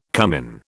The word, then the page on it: cummin: British English